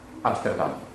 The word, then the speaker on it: Amsterdam: Invece i Paesi Bassi del Nord sono la Repubblica delle Province Unite di matrice protestante, con capitale Amsterdam.